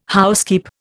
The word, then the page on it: housekeep: Indian